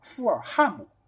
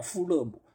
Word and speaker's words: 富勒姆: 你照道理来说应该叫富尔汉姆，对吧？如果是错误的那个翻译。但是呢，它也是省略了中间 H 的这么一个发音的一个音节，所以它才会被翻译成叫富勒姆。所以这个其实又是正确的，而且是 L 和 A 的连读，所以成了勒这个音就出来了。